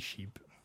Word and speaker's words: Sheap: Enfin, Sheep, je ne sais pas s'il prononce ça comme ça. C'est parce qu'il y a des points. Donc, S-H-E-A-P, mais c'est Sheep. – C'est Sheep.